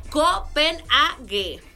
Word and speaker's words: Copenhague: Ok, dice que se pronuncia Copenhague, porque la H es muda.